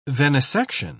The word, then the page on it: venesection: British English